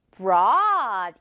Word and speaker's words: brought: Brought 이렇게 발음돼요. 이것도 너무 생긴 것만 보면 뭔가 brought, brought 어떻게 발음해야 되지 싶은데 똑같이 어 발음인데 앞에 br만 있는 거예요.